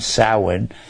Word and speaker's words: Samhain: It's pronounced S O W E N, Samhain. It's spelled Sam Hane.